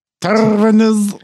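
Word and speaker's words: Terranes: How are you pronouncing that? Taranas. Floral Taranas.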